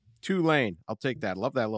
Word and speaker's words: Tulane: How's our pronunciation on Tulane? Doing better, Ross? – Yeah. Yeah. Perfect. – Right. Good. Good. – I think I'm going to go with Tulane to cover in this one.